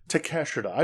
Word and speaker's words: Takeshita: Eddie Kingston versus Kaneshita. I will never fucking pronounce it. Takeshita. I just call him Takeshita. Takeshita.